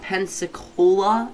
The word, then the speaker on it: Pensacola: Okay, suspected Pensacola? Is that how you say it? – Yes, that is.